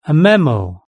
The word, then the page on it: a memo: British English